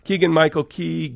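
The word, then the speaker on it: Keegan-Michael Key: Keegan Michael Key,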